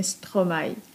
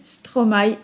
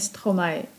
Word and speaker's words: Stromae: I to nie byle jaki mistrz, bo sam maestro, a konkretnie Stromae, bo tak właśnie należy wymawiać ten pseudonim sceniczny. Stromae, a nie Stromae.